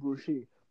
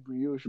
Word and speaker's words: brioche: That thing actually fucked me up because I came here saying brochet, but apparently it's pronounced brioche.